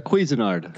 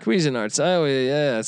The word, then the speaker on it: Cuisinard: How do I say this one? Cousinard again. – There you go. Cuisinard. – Cuisinard. Something like Dan Quisenberry. All right. – It's French.